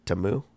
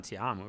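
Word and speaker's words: Ta'amu: Tamu, yeah. – It's not Tiamu, right? It's Tamu. Jordan Tamu.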